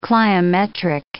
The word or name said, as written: cliometric